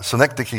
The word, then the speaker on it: synecdoche: Synecdoche is the proper pronunciation.